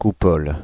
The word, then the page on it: coupole: [ku.pɔl]; /ku.pɔl/